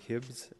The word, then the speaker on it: Hibbs: Yeah, it's Ralph Hibbs, H-I-B-B-S.